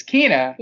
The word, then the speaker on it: Kena: I just feel that there needs to be one more E in her name. Kena. For it to be pronounced correctly. – Like, yeah. – Because. – Or Kena. – Yeah. It's Kena.